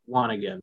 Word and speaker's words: wanigan: I remember I got teased a little bit, but I think it's pronounced Wanigan. So did I do that one right? – You got it.